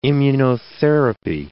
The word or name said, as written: immunotherapy